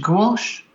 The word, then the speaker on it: gouache: I think towards the end, he just started to introduce, I never know how to pronounce this, gouache? Gouache? – Oh, yes, yeah. – Probably gouache. That's what Don introduced me to the stuff. He called it gouache.